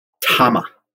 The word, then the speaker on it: Tama: How do you pronounce the name? And I was told by Charlie Hayashi, Tama.